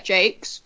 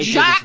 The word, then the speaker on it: Jacques: So, we've got the amazing writer, Juliet Jakes, whose name I've been pronouncing wrong for fuck knows how many years, because I thought it was like... – I Jacques!